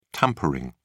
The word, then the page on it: tampering: British English